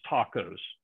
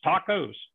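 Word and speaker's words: tacos (πτωχός): The word poor is an interesting Greek word that's hard to pronounce, that I'm just going to pronounce tacos. That make it easier for you? It's spelled P-T-O-K-O-S, and you don't pronounce the P, so it's tacos.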